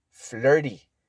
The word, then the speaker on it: flirty: Es como la palabra fiesta. Party. La T es muy suave. Entonces se escribe flirty, but you say flirty, like party, flirty.